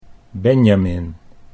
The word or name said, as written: Benjamin